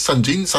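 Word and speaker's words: Sangean: Więc troszeczkę z pogodą, ale tak, firma Sanjin, może tak to mówić, Sanjin, tak się ją pisze się.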